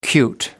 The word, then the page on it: cute: American English